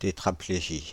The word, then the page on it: tétraplégie: /te.tʁa.ple.ʒi/